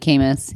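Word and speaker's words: Caymus: It's definitely Camus. Yeah. – Camus. – I do know that one, yeah. – Because it rhymes with anus.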